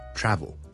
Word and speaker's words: travel: 譬如我讲 travel 这个字 T-R-A-V-E-L travel，我们读 travel 的，我们就不会读 travel 的。所以呢即使是你是想讲英式英文呢，你都不会读成 travel 的， but it's travel travel travel。